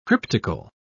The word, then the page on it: cryptical: British English